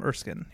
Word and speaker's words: Erskine: And that's Erskine, right? – Yes, Abraham Erskine.